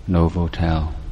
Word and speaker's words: Novotel: ป้ายของโรงแรม Novotel แต่เนื่องจากว่าไม่ได้ตั้งใจดูเห็นแวบๆอย่างนี้แล้วลืมไปว่าตรงนั้นเป็นโรงแรมอาตมาไม่ได้อ่านว่า Novotel